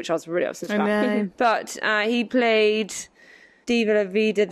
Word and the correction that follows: Viva la Vida: da diva, da, I always say it wrong. – Viva la vida.